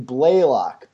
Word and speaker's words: Blaylock: It's a long A in Blaylock, but close enough.